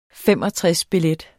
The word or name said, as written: 65-billet